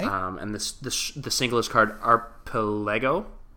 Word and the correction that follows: Archipelago: Is that how you pronounce that? – Is it Archipelago? – That's the one. Yeah. Okay. It's like, yeah, that's the word.